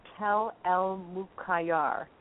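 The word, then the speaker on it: Tell el-Muqayyar: Yeah, and they're saying the name of the city is Tel El Mukayar.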